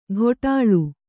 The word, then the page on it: ಘೊಟಾಳು: ghoṭāḷu